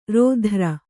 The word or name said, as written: ರಾತ್ರೆ